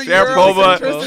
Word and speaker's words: Sharapova: You can say Sharapova, yeah. but you can't say Antetokounmpo. – Wow, the Sharapova. Sharapova's three syllables.